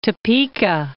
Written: Topeka

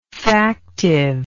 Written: factivity

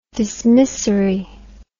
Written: dismissory

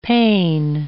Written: pain

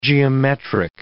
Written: geometric

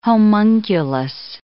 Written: homunculus

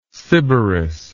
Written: sybaris

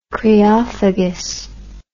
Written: creophagous

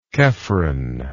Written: chephren

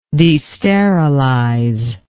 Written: desterilize

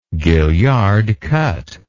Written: gaillard cut

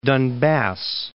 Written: Donbas

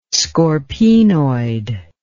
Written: scorpaenoid

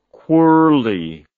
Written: quirley

